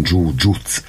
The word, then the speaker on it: jiu-jitsu: A jiu-jitsu, amit Japánban is hívják, japánosan jiu-jitsu a rendes kiejtés, de ugye halljuk kiejteni jiu-jitsunak, mint hogy mi is mondjuk, ez ilyen inkább angolos, vagy a németes, meg a brazil területen a jiu-jitsu, ugyanarról van szó, csak hogy a kiejtés más,